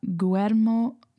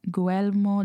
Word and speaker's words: Guillermo: And it is, I know I'm going to butcher the name, Guel- Guillermo, Guillermo del Toro.